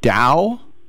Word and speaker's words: dhow: Dhow? – Right.